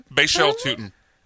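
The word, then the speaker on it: Bhayshul Tuten: How do you say it? – Bashel Tootin. – I think I got it right. Did I not get it right? – Yeah, you were pretty close.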